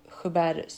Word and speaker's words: Joubert: Men är det franskt? Ja, precis. Joubert syndrom. – Så det är ett franskt efternamn.